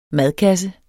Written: MADKASSER

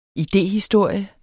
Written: idehistorie

